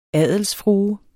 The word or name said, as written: adelsfrue